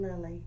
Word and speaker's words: Lilly: This is Christy Frazier, and I'm here with Charmin Lilly.